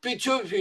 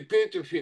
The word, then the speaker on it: Петёфи: Клуб Петёфи или Петёфи, как его по-разному называют, потому что у венгров не всегда ее под ударением.